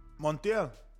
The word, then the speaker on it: Montiel: Montiel.